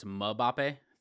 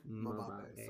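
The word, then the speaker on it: Mbappé: You have to pronounce it like Charles Barkley. It's Mbappe. Thank you. – Mbappe. Sorry. – Yeah, I've been saying it wrong my whole life.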